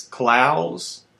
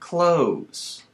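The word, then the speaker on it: Clowes: It's Klaus like plows, not clothes like clothes. It's Klaus, Daniel Klaus, author of Ghost World.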